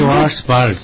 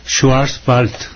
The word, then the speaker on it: Schwarzwald: Schwarzwald. – Ya la W no es B, sino Wald. – Schwarzwald. – Una chica. B. Casi. V. Willy de Winter, buen ejemplo para la pronunciación de la letra W.